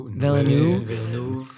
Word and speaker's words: Villeneuve: Villeneuve.